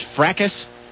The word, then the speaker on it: fracas: Is that word pronounced fracas? Right. Is that how that's pronounced? – I believe so.